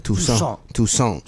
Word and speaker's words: Toussaint: That's how you say his name? – Toussaint.